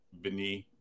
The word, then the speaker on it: Bieniemy: I'm trying to get Eric Bieniemy.